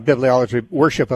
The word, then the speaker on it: bibliolatry: Moreland, and he was talking about, I think the term was bibliolatry, or I might be mispronouncing that. Yeah, bibliolatry, worship of the Bible, yeah.